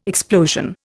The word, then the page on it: explosion: Indian